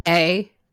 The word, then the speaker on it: Choi: So, of course, the news of the hour, as mentioned at the top of the show here, is that Sergeant Choi Minho. – Choi Levante Minho. – Isn't it pronounced Che? – Yeah, it's Che.